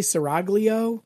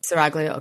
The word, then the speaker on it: seraglio: Sort of like the King and I meets Harem Honeys and how do you say that word? Seraglio? Seraglio? – Hang on, where are we? – Harem Honeys and Seraglio? Seraglio? – I would say Seraglio. – Seraglio, okay.